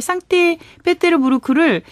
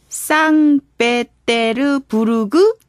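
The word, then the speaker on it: 쌍떼 페테르부르크 (Санкт-Петербург): 후후 이렇게 보내주셨는데 재미나게 쌍떼 페테르부르크를 쌍베테르부르그 이렇게 올리신 분도 계시고 쌍떼 베체르 이거 어감이 이상한데요.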